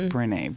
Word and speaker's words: Brené: I think it's pronounced Breen. Her name is B-R-E. – Brené Brown. – Brené. Mm-hmm. It's Brené. Brené Brown.